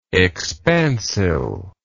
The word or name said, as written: expansile